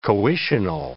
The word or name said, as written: coitional